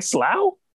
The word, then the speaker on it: slough: S L O U G H. Like Slough? – Yes.